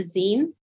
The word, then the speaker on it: zine: If they're creating a small little piece, they have this thing called a zine in the arts. So it's spelled Z-I-N-E, and it's like the second half of the word magazine.